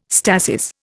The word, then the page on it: stasis: Indian